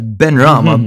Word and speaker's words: Benrahma: Declan Rice and Suchek were the holding midfielders, with Lucas Paqueta, the former Milan man, in the pocket over there, with Benarama, Bowen, playing off the shoulders of Antonio. Sorry, I fucking butchered that. Benrahma. Benrahma. – Benrahma, yeah.